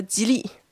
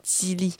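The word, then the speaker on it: Jili: Même je sais plus avec vous comment prononcer mon nom. Déjà, en fait, les Chinois m'appellent Tsili, tout simplement. Et en chinois, pour s'adapter, pour vous dire aussi, moi, pour faciliter la prononciation, on dit toujours Tsili.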